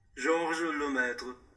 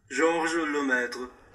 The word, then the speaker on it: Georges Lemaître: I would like to click here for you guys to listen how the name of Georges Lemaître is actually pronounced in French. – Here we go. – Georges Lemaître.